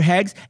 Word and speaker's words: Heggs: H-E-G-G-S. – Yeah, Luther Heggs. And Heggs rhymes with segs.